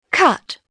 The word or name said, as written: cut